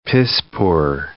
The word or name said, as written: piss-poor